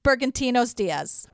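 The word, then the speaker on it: Bergantiños Díaz: It's Jose Carlos Bergantinos Diaz. – Wow, good for you. – Just like it's spelled.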